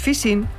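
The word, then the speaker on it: phishing: Recuerde la expresión popular, por la boca muere el pez, pues fishing se pronuncia en inglés casi como fishing, nuestra pesca.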